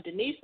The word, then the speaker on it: Denise: And me, I'm Denise.